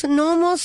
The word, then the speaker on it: gnomos: ¿Tú sabes cómo le dicen gnomos? ¿Gnomos se le llama? Gnomos sí, se le llama, que se escribe con la G primero, sí.